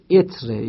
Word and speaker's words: عطر: مثلا بگم فلانی دائما عطر میزنه، مثلا بگیم که نه تلفظ صحیحش اتره یا اتره، مثلا فرض کن.